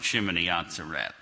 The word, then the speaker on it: Shemini Atzeret: Now, I pronounce it a little different than some others do. There's two different basic pronunciations of Hebrew. One is the Ashkenazi, the European style, and the other is Sephardic, which is supposedly the official way to pronounce things in Israel. So anyway, it's the same thing as Jeremiah was talking about and others, so I call it Shemini Atzeret.